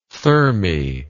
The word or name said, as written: thermae